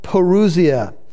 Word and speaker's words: parousia: The word is spelled P-A-R-O-U-S-I-A, parousia, or some people pronounce it parousia.